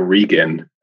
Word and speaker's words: Regan: Yeah, Michael, I struggled with the pronunciation as well, Jim. It's actually Michael Regan, as I understand it.